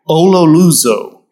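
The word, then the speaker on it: ololuzo (ὀλολύζω): The Greek is pronounced this way, ololuzo.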